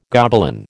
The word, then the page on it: Gobelin: American English